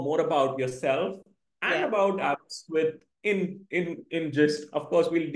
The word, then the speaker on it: Aberystwyth: So I break it down to three parts. So it's abs, as in abs that we all yearn for, wrist, and width. So it's Aberystwyth, and for short form is ABBA.